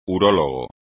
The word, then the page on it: urólogo: espagnol